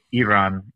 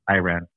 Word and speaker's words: Iran: That's pronounced Iran. – Excuse me. – Not Iran. Okay.